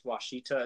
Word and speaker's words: Ouachita: At least I assume it's pronounced Ouachita. It's not spelled with a W at all. But I looked up the pronunciation. – That's Isn't it like said. O-A? – Yeah, it's O-U-A-C-H-I-T-A.